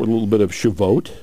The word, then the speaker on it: Shavuot: Shavuot.